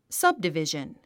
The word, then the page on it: subdivision: sʌ́bdivìʒən; サブディビジャン